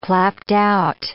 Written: clapped-out

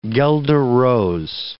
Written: guelder rose

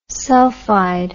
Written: sulphide

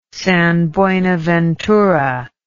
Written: San Buenaventura

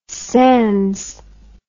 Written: sands

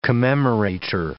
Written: commemorator

